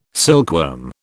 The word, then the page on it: silkworm: British English